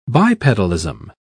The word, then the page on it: bipedalism: British English